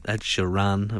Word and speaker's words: Ed Sheeran: Ed Sheeran, of course. That's how you say his name.